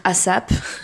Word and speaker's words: ASAP: C'est ASAP. Ça s'écrit A S A P. En français, les gens vont dire ASAP. Ça se dit ASAP.